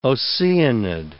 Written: Oceanid

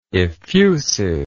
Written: effusiveness

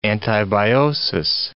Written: antibiosis

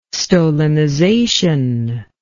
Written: stolonization

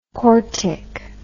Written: cortic

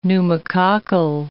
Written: pneumococcal

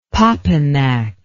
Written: popinac

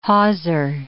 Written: hawser

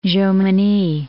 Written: Jomini

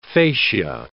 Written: facia

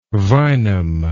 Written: vinum

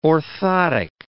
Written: orthotic

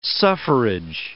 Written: suffrage

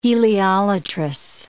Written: heliolatrous

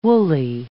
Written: wooly

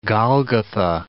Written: Golgotha

